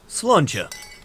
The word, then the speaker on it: Sláinte: Sláinte. – Did I say it right? – Like a native speaker.